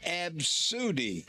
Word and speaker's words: ABCDE: ABCDE, pronounced Absudy.